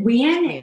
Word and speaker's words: Nguyen: We pronounce it Nguyen. It's like W-E-I-N.